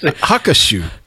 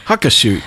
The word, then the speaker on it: Hakushu: it's spelled with an H. Where do you get Hakushu? Hakushu.